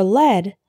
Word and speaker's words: lead: The word LEAD can be pronounced lead or led.